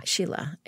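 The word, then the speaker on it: Scilla: It's S-C-I-L-L-A. So mm-hmm. It's an Italian spelling of an Irish name. – Mm-hmm. But pronounced Sheila. – Sheila.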